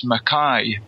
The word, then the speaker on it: Mackay: Well, actually, I've never corrected anyone to date about this, but my second name is actually pronounced Mackay, as in, you know, your eye. So my name is Gordon Mackay,